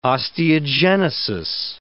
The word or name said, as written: osteogenesis